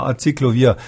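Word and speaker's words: Aciclovir: Frau Frau Schuritz aus Fulda fragt per Mail, ob der Wirkstoff Acyclovir die Virusvermehrung hemmen könnte. – Habe ich das jetzt richtig ausgesprochen? Ja, ja, Acyclovir.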